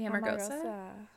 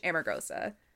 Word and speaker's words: Amargosa: The Amargosa. I'm butchering it because it is like I'm pronouncing it like a white bitch. Amargosa. It's Spanish for bitter, I think.